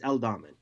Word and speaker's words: Aldhamen: It's Aldhamen.